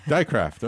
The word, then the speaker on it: Dijkgraaf: How do you pronounce this? – Dycraft, okay.